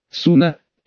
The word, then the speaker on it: Суна: Сейчас, насколько мне известно, Викки ведет переговоры с твоей Суна. Суна, поправил я парня. Он сделал ударение на первой гласной. Суна.